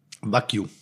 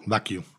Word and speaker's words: Wagyu: Wagyu. – Wagyu? – Wagyu. – Ah, okay, da habe ich mich immer gefragt. Ich habe oder nenn es einfach japanisches Rind. – Okay, Wagyu.